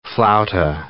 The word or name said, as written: flauta